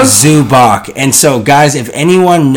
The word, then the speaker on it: Zubac: Zubac. – And I say Zubac because so, it's spelled Zubac. But Corbin says Zubats and thinks it's this.